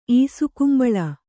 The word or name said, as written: ಈಸುಕುಂಬಳ